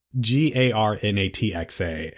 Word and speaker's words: Garnatxa: It's like Garnacha. It's Garnatxa. And I couldn't quite get it on my tongue properly.